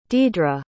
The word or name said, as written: dedra